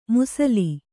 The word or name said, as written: ಮುಸಲಿ